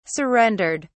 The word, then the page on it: surrendered: British English